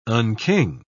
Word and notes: unking: British English

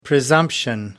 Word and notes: presumption: American English

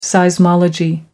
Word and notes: seismology: American English

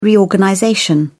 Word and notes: reorganization: British English

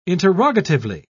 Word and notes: interrogatively: British English

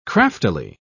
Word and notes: craftily: British English

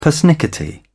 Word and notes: persnickety: British English

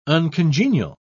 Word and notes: uncongenial: British English

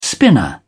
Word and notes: spinner: British English

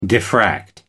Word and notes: diffract: American English

